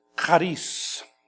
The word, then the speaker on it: charis: It's the Greek word charis. Charis. It's like you're clearing your throat.